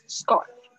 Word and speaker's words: scarf: 好，接下来是这个 scarf，注意一下 c 的发音，因为这个上一节的购物我们已经讲过 scarf，这个 c 呢，它在 s 后面的轻音的时候会变成 g 的声音 scarf，然后还有 gloves，啊 gloves 就是 g 加上 love，但是呢，因为手套有两只，所以呢要记得加 s gloves gloves，OK。